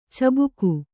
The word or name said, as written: ಚುಬುಕು